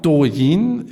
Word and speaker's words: Douyin: Das letzte Unternehmen, was ich mitgebracht habe, ist Douyin. Jetzt korrigiere ich mich selber, weil ich gemerkt habe, dass es nicht richtig ausgesprochen wird. Das letzte Unternehmen ist Douyin.